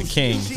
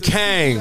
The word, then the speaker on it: Kangs: But you ain't saying it right. It ain't the Kings. It's the what? – The Kangs.